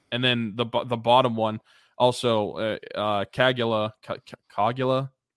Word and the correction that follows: Caggiula: How do you pronounce his name? – Got Caggiula. called up. Drake Caggiula. Man, I was way off. Drake Caggiula. I'm sorry to your family. I I mispronounced that name so bad.